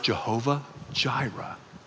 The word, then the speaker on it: Jehovah Jireh: It's pronounced Jehovah Jireh.